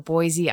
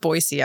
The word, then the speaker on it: Boise: but you came all the way from Boise, Idaho. – It's pronounced Boise, actually.